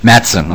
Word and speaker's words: Matson: Matson. – Okay. – The I is silent like the P in swimming.